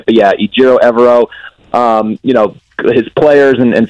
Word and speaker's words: Ejiro Evero: Ejiro Evero? – Ejiro Evero is sort of how we've come to know that his name is pronounced.